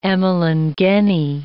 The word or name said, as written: emalangeni